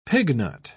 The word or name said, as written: pignut